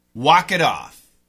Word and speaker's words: walk it off: Walk it off. Walk, W-A-L-K, it. Off, O-F-F. Now listen to the pronunciation. Walk it off. It's a flap T.